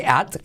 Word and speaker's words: at: Y la T de at tampoco casi se dice.